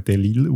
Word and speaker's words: Delisle: Also man schreibt das Delisle, aber ich habe mich informiert, das spricht man Delisle aus, offenbar.